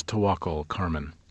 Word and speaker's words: Tawakkol Karman: Her name is Tawakkol Karman. – Tawakkol Karman.